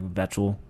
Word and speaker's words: Weichel: When it's not Wetchel, it's like Vetchel. – Weichel. – Weichel. – Whatever. It's a W like Weichel, but it's pronounced like with Weichel. A V. It's German. Daniel Weichel.